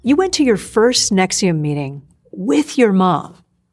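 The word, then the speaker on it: NXIVM: Executive Success Programs was part of a company with a strange name, pronounced Nexium.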